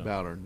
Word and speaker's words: Bowdern: Halloran and Reverend William Bowdern. Is that the correct pronunciation? – Sounds like it. Like so. Bowdern, yes.